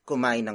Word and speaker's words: kumain: The first word is kumain. Kumain. Kumain. Kumain is spelled as K U M A I N.